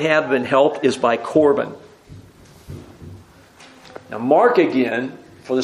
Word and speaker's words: Corban: If you can say Corban, you pronounce the Hebrew word.